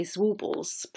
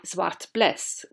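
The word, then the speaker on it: Zwartbles: In English we typically say Zwartballs, pronouncing the BLE as we would in the words able or possible. But as a Dutch speaker, my instinct is to say Zwart Bles, which literally means black blaze.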